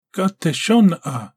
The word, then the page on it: Gateshona: Galindri